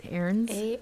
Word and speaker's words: Arnes: Cliff's boss, Dave Lowen, who ran Camp Aarons, I believe I'm pronouncing that right, excuse me, A-R-N-E-S, Aarons? – A-R-N-E-S, Aarons? – Aarons?